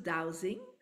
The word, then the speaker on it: dowsing: we pronounce it dowsing. – Oh, Yeah. – douse. That's why I'm like, douse, douse. Okay, I understand the word douse, but what does it mean in terms of feng shui and manifesting?